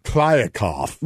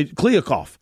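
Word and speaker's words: Kliavkoff: Klyavkov. – With a V in the middle. Klyav, but you say it really quick. – I think it's Klyavkov. – Klyavkov, is it? Okay, so I Klyavkov.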